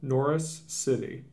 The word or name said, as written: Norris City